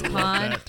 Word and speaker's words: Khan: My last name is Khan, K-H-A-N.